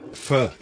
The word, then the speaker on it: feu: Feu is the word in French. – What is it? – Feu. It's F-E-U and pronounced feu, rather than fe or foo. But that's the mispronunciation.